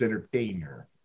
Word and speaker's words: entertainer: You pronounced the T way too hard in that one. I'm pretty sure you just replace it with a D if you're going to say it. Sports entertainer. – Entertainer.